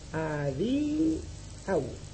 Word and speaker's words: adiau: Con la U supersignada, tiene tres vocales. La A, perdón, la A, la I y la A. La U no es vocal, es semivocal, ¿eh? Luego tendrá tres sílabas y se pronuncia a, di, au, que significa adiós.